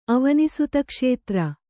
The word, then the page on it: ಅವನಿಸುತಕ್ಷೇತ್ರ: Kannada